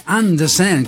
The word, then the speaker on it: Andersen: Andersen, questa è la pronuncia giusta in danese.